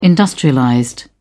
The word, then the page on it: industrialized: British English